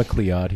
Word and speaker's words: McLeod: Brecken McLeuchy. – I got to learn how to pronounce people's names. It's because I'm sick. – It's Brecken McLeod. He likes it when you pronounce it very phonetically.